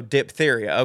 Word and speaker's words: diphtheria: And no, we don't pronounce the H after the P, so diphtheria. Okay. Uh, there are a lot of those superfluous H's in medical words like aphthous and ophthalmology.